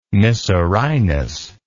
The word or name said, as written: mycerinus